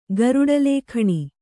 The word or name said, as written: ಗರುಡಲೇಖಣಿ